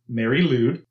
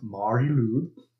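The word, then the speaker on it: Mari Lwyd: So there's this Welsh tradition called Mary Lud, or I guess more correctly pronounced Mari Lud.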